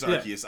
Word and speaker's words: Arceus: Arceus, yeah, it is Arceus. Yeah. I never say it right.